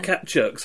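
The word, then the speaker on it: Kaptchuk: So you talk about a guy, Ted Kapchuk, is that pronouncing it correctly? – Yeah, Ted Kapchuk. – Kapchuk.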